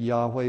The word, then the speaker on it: Yahweh: Yahweh or Jehovah, some say Jehovah. I think Yahweh is the correct pronunciation of that Old Testament name for God.